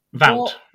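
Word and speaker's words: Wout: Is that how we're pronouncing it? Vought. – Vout. – Vout. Veghorst.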